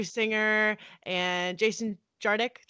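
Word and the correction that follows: Tartick: Jardick, is my saying that right? – Tardik, Tardick.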